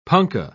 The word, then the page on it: punkah: British English